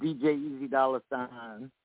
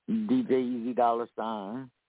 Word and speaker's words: DJ EZDOLLARSIGN: This is DJ Easy Dollar Sign.